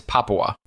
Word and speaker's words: Papua: I kept calling it Papua or something like that. It's Papua.